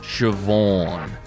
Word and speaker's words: Siobhan: It's pronounced Siobhan.